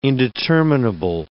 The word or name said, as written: indeterminable